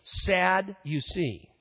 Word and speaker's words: Sadducees: Just pronounce their name. Sad, you see.